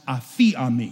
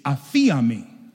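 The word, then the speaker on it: aphiami (ἀφίημι): The Greek word for forgive there in Matthew chapter 6 is pronounced aphiami. It'll be a fun thing for you to say on your way home. It's aphiami.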